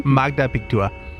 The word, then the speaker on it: Magda Pichtua: So, and I think that's a How do you spell that? – M-A-G-D-A, Magda, P-I-C-H-T-U-A. – Say that again? – Magda Pictua. – Pictua. – Pictua. – Pick to it.